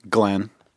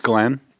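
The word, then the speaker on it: Nglenn: Glenn. – Huh? – Glenn.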